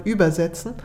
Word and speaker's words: übersetzen: Der ist im Deutschen sehr viel deutlicher, auch wenn man die Betonung da leicht verschieben muss. Also nicht mehr übersetzen, sondern übersetzen mit dem Boot von einem Sprachufer zum anderen.